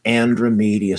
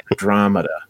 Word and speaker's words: Andromeda: The Andromeda Strain. – Andromeda. – I'm sorry. Thank you. Andromeda. – It's one of my favorites. You have to pronounce it right.